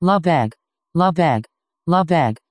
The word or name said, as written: Lebesgue